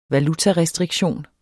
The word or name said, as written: valutarestriktion